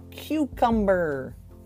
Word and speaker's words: cucumber: It's known as the cucumber. Cucumber. C-U-C-U-M-B-E-R. Cucumber. Emphasis on the first syllable.